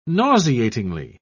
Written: nauseatingly

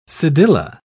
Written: cedilla

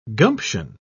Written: gumption

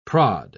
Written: prod

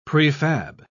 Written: prefab

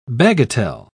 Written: bagatelle

